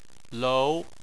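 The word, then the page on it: lou: tone 2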